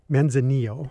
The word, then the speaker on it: Manzanillo: Manzanillo.